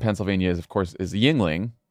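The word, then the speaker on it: Yuengling: And he called it Yangling. – I mean, that, you'd have to leave the state. It would be like mispronouncing. Yingling or something. It was one of the syllables was very badly pronounced, which is crazy to me because, like, what are you doing, man?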